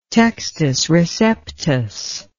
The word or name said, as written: textus receptus